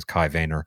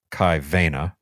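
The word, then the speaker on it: Kai Waehner: His name is Kai Vayner. That's the American pronunciation. Kai Vayner.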